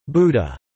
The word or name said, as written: Buddah